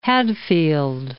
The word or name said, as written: Hadfield